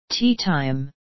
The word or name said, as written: teatime